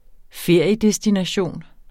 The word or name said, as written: feriedestination